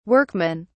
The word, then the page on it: workmen: British English